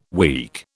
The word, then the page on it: week: British English